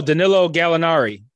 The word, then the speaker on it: Danilo Gallinari: Daniel Gallarni? – Oh, Danilo Gallinari. – I totally butchered his fucking name.